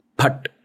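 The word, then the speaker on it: फट्: Phat. ठीक है और इसमें the pronunciation has to be PH, not F. – Phat. – Ha, not fan. Hmm. Because the pha pronunciation is not there in Sanskrit.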